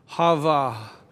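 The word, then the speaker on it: Hava (חַוָּה): I shared a Christian story of fiction with you about the Hava, the way the Hebrews would pronounce Eve.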